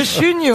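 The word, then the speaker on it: Chuniaud: Monsieur Chugnot.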